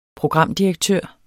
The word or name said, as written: programdirektør